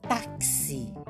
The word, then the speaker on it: táxi: Eu tenho a palavrinha eu pronuncio T A K S I táxi. Então eu tenho cinco fonemas.